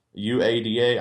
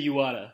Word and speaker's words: Uada: Dark Funeral and Uada. UADA. I don't know how to pronounce that. – I say Uada.